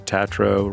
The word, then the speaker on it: Tatro: It stars Molly Gordon, Ben Platt, and Jimmy Tatro, rhymes with bro,